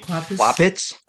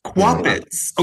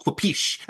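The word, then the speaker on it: Kwapisz: How do you say the last name of this penciler? It is Gary Quapitz. – Quapitz. Okay, in my mind, it was like Quapish, and I was thinking, oh, that's like a perfect sound effect for a whip on a page for like a letter.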